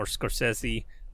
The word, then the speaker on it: Scorsese: digital and um, you know, uh, she Scorsese became a fan of hers, and or Scorsese.